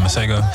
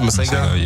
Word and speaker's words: Masego: Speaking of beats, I heard y'all was in here listening to FKJ and Mace God. – Oh, Masego? – Yeah. Is that how you pronounce it? Masego? – Yeah.